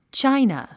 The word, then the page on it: china: tʃáinə; チャイナ